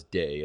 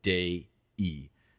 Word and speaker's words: Dei: It's D. E-I, and you pronounce that not as day, as in have a nice day, but day-e. In Latin, you always pronounce all the vowels. You don't, well, unless it's a diphthong.